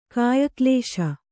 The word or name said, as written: ಕಾಯಕ್ಲೇಶ